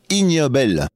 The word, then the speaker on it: Ig Nobel: Le prix du jour et dernier de la série Bête de concours s'appelle IG Nobel, qu'on prononce ignoble.